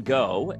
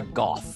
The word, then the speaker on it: Gogh: Vincent van Gogh, and that is how Americans pronounce his name. It is pronounced differently in his native Dutch, but I am not Dutch, so I'm not even going to try. But it's closer to Gogh.